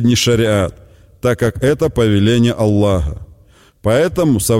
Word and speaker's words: Аллах: Например, если человек произносит «нет божества, кроме Аллаха», то имя Создателя Аллаха он должен произнести правильно. Здесь особое внимание уделяется последней букве «г», напоминающей по произношению украинскую «г».